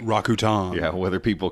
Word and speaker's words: Rakuten: so yeah, it officially from that commercial we learned it is Rakuten.